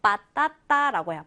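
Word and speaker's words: patata: 자, 스페인어 발음으로 요 단어는 빠따따라고 해요. 빠따따.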